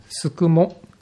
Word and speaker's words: 宿毛: 宿毛、宿るに毛ですね、宿るに毛と書いて宿毛市という場所があります。